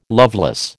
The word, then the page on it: loveless: American English